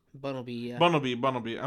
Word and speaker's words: Bunnelby: Bumblebee. I'm saying it right? – Bunnelby. – I'm saying Bumble. – Yeah. – Bunnelby.